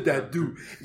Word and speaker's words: Dabdou: Tiene un nombre maravilloso que es Dabdu. Se escribe Dabdou, pero se pronuncia Dabdu.